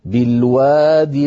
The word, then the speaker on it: بالوادي: بالوادي انظر إليها أيضا أصلها بالوادي، نعم بياء في آخرها، لكن هذه الياء ستسقط في النطق للتخلص من التقاء الساكنين، فسنقول بالوادي المقدس. فإن وقفنا على الأولى التي كتبت من غير ياء على نية الوصل نقف كالخط، أي نقول بالواد، وأعود فأقول كل هذا اضطراري أو اختباري.